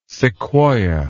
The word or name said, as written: sequoya